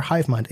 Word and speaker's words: HYVEMYND: Both places are HiveMind, HYVEMYND, because back when I made my little AIM screen name, mm-hmm, spelling with I's was taken.